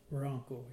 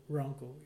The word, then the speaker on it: Wronko: W-R-O-N-K-O. Ronco, yeah. It's a W-R. Kind of hard to say Ronco. You want to say W-O-R, but It's W-R.